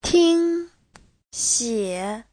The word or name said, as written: ting1xie3